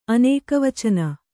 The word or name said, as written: ಅನೇಕವಚನ